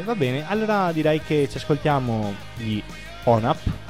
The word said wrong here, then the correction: ONAR: si legge così. – Ma si scrive ONAR.